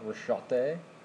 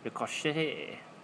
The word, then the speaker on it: ricochet: And yet it's spelled with a CH. – It's French. – So it's not Rochette? – Rochette. – Rochette. – Like that. See, No, it'd be more like ricochet.